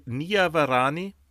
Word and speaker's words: Niavarani: Ja, steht ihm äh, Michael Niavarani, so spricht man aus.